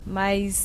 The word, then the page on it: mais: brsp